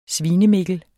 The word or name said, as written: svinemikkel